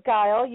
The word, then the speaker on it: Geil: It is Geil.